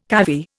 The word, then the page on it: cavy: Indian